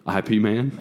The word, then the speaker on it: Ip Man: It's just IP. – IP Man? – It's literally just IP Man.